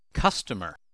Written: customer

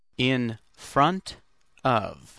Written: in front of

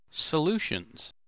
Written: solutions

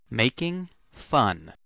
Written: making fun

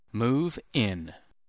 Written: move in

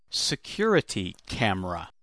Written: security camera

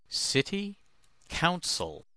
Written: city council

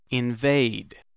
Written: invade

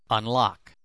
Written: unlock

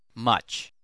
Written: much

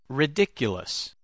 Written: rediculous

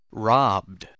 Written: robbed

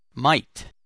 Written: might

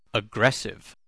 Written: aggressive

